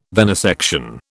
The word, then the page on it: venisection: British English